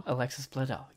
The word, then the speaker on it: Alexis Bledel: So she said, a few nights ago, I had a dream that I was at a convention and Alex Bledel. Did I say her name right? – Alexis Bledel. – Yes, yeah. Got it.